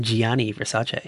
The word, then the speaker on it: Gianni Versace: In Gianni, I'm saying his name right this time, Gianni Versace.